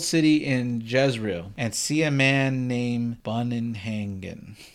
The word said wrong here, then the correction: Bugenhagen: I was bad at that one. Sorry. – Can you spell it? I don't remember. – Bugenhagen. – Oh, yeah. Bugenhagen. – Bugenhagen. Yeah, that's what I said.